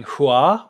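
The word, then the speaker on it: Hua (花): And this play is the thing that actually popularized Mulan's surname as being Hua, or pronounced Fa in Cantonese, which means flower.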